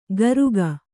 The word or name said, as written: ಕಾರುಕ